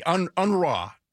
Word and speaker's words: UNRWA: UNRWA, as you have to very subtly pronounce the R. All UN money goes through UNRWA in Gaza.